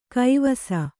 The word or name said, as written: ಗವಿಸು